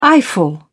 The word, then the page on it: eyeful: British English